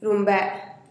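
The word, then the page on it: rumbee: Teotitlán del Valle Zapotec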